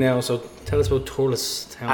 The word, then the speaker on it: Thurles: Thurles.